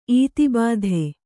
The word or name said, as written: ಈತಿಬಾಧೆ